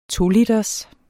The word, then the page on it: 2-liters: toliters